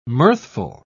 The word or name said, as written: mirthful